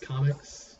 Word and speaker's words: Comix: comics? I don't know. How do you say it? – Comics, but it's spelled with a K and an X.